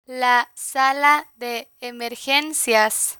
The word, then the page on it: la sala de emergencias: spanish